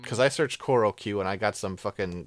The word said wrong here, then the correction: Choro Q: Oh, Choro Q. – It's, well, I guess it is Choro. How is it pronounced? Let me see. – I mean, that's how you'd say it in Japanese, so.